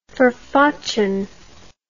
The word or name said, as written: forfochen